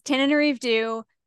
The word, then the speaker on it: Tananarive Due: So it's Tananarive Due, The Reformatory.